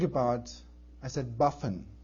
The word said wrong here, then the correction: buffoon: How do you pronounce buffin? He said, buffin, buffin, buffin. Oh, you mean buffoon? Correct? Buffoon. Yes, buffoon. Okay, I got that.